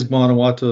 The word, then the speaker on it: Guanajuato: And why don't we start off with a client of VSA Capital, which is, and I always pronounce it wrong, but Guanajuato Silver. – Yes, it is Guanajuato Silver, the TSX listed silver developer in Mexico.